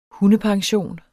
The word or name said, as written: hundepension